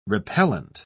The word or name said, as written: repellent